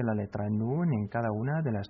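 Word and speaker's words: Nun (نون): Nun.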